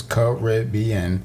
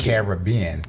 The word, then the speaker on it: Caribbean: people use Caribbean with the emphasis on the second syllable and Caribbean with the emphasis on the third syllable and a slight emphasis on the first.